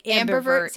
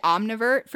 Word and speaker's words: ambivert: Yes. So ambidextrous, but ambivert. – Okay. That makes sense. Ambidextrous, ambivert. Okay.